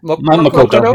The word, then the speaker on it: Makoto: Mikado. Did I say that right? Mikado? – Makoto, yeah.